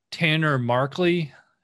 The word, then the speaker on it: Tanner Markley: Today, I'm talking to Tanner Markley.